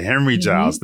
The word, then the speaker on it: Henri Giles: It's Giles, okay. And it's Henry. – Henry, it's as in Henrietta. – Got it, Henry Giles.